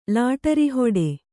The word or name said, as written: ಲಾಟರಿಹೊಡೆ